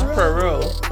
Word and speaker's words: Poirot: I remember how to say his name. Poirot. It's Poirot.